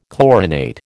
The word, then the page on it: chlorinate: American English